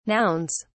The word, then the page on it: nouns: British English